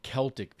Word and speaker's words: Celtic: You know, certainly the Celtic people, that's the proper pronunciation, not the Boston Celtics, but the Celtic people.